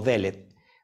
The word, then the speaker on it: Dalet: Eh, Dalet, lo que estás viendo aquí, pues es la, letra hebrea que tiene el valor en gematría de 4, pero eh, Dalet también se puede pronunciar como Delet porque Delet significa puerta.